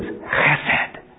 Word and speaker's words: chesed: The Hebrew word is chesed. Chesed. You know you say it right if the saliva goes to the fourth pew back.